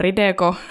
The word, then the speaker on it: Rideco: Sitten Rideco, niin kirjoitetaan ja lausutaan savolaisittain Rideko.